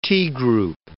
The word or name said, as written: T-group